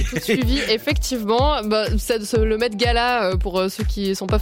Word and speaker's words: Met Gala: Non, mais je crois que ça se prononce Met Gala.